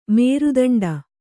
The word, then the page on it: ಮೇರುದಂಡ: nēru daṇḍa; Kannada